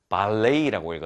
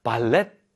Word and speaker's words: ballet: 발레이라고 읽었죠. 우리가 흔히 알고 있는 이 발레이입니다. 발레. 저는 발레를 잘 못하지만요. 그래서 발렛 같은데요. 이게 발렛 같은데 발레이, T 발음이 무음입니다. 그래서 발레이라고 읽어야 된다는 점 알려드립니다.